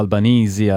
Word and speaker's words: Albanese: Queste le parole del parlamentare Anthony Albanese, o Albanese, a pronuncia australiana, che ha ammesso di essersi sbagliato, che il sistema di respingimenti delle imbarcazioni, dei centri di detenzione, funziona.